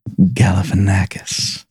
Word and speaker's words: Galifianakis: Galifianakis.